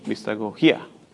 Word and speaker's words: mistagogía: Me parece que tiene acento en la última I, mistagogía.